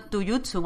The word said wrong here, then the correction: Tatsujin: o algo así, es que no sé cómo se dice. Tatsujin. taiko no Tatsujin. – Taiko, no Tatsujin.